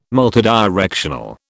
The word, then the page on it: multidirectional: British English